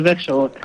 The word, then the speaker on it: Växjö: Vous nous appelez de Växjö, en Suède. Je ne sais pas si j'ai bien prononcé. – Oui, c'est Växjö.